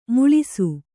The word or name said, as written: ಮುಳಿಸು